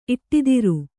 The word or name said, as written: ಇಟ್ಟಿದಿರು